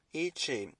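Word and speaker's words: Yi qi: To determine the geological layer and age, the team called the species Yinquai, pronounced Chi, Yi Chi, which means strange wing in Mandarin.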